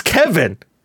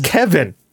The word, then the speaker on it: Cefn: It's Kevin. It's pronounced Kevin. – So seven's pronounced Kevin. – C-E-F-N is pronounced Kevin. It's the Kevin Druids.